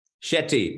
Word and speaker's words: Shetty: Shetty, but you did you did a good job for the first time.